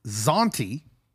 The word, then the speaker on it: Zauntee: I am here with Zauntee, and sure.